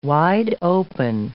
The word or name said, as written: wide-open